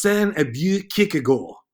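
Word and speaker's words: Søren Aabye Kierkegaard: And she said, it's Sen Abue Kierkegaard. That's how you say it.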